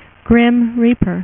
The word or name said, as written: Grim Reaper